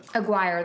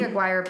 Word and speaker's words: Aguirre: Aguirre, that's how you say it. – I think so, yeah. – I've been just going like Aguirre, and I think that's not right. – Yeah. – I think Aguirre probably makes more sense.